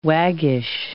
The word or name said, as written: waggish